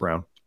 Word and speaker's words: Braun: It's hard to find, but a guy with that kind of like is Christian Braun, for example, Christian Braun, pronounced Brown.